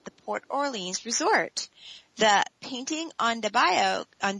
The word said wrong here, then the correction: bayou: on the why can't I pronounce that? Bio? – Bayou. – Bayou. – Bayou. – There we go.